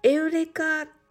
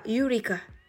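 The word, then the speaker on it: Eureka: エウレカというのはギリシャ語で英語ではユリカと発音します。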